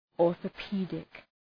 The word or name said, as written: orthopedic